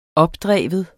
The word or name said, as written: opdrive